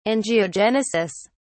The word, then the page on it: angiogenesis: British English